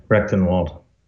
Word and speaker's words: Rectenwald: God, I hope I didn't butcher that. – No, that's good. Rectenwald.